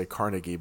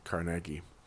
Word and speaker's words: Carnegie: I know Americans say Carnegie, but I think it actually is Carnegie.